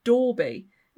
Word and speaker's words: Dolby: Dolby is pronounced Dorby in the local dialect, and Dawlish Cashan, Irving's farmhouse, was known locally as Dorby Spook House.